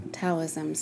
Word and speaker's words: Taoism: So I bring up Buddhism because, you know, that's one of the Eastern practices that Alan focused on, in addition to Taoism. Is that how you pronounce it? – Yeah, Taoism.